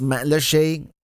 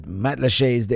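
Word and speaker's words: Matlacha: Just your most immediate friends and family and fellow Pelican heads, and that's the island of Matlashe, which is it's pronounced Matlashe. It's kind of written Matlasha, but Matlashe is the proper way to pronounce it.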